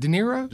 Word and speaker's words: De Niro: Robert De Niro. How do you say it? De Niro. – Yeah, phonetically.